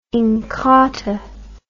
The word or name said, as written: inkatha